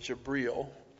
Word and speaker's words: Jibril: And when you listen to him speak, you have to listen carefully because they pronounce it Jibril. And at first when I heard the word Jibril, I didn't know exactly what that meant, but they meant Gabriel, because they think Gabriel gave these revelations to Muhammad.